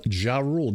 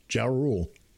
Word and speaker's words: Ja Rule: No, no that's how it. Ja Rule, J A capital R U L E, Ja Rule.